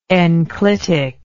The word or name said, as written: enclitically